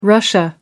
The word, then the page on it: russia: American English